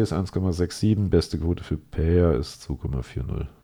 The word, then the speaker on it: Pereira: Das ist Alex Pereira, also ein bisschen das R wird auf Portugiesisch mit H ausgesprochen, gegen Israel Adesanya.